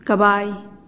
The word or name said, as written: kabai